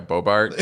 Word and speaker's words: Boebert: like Lauren Boebert, is that her name? Lauren Boebert? – Yeah, Boebert.